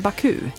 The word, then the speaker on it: Baku: Man säger inte Baku, man säger Baku. – Ja, på, med en försvenskat uttal.